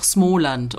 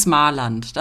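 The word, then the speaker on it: Småland: Ähm, arbeitet heißt ja auch Smoland und nicht Smarland.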